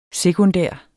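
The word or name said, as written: SEKUNDÆRT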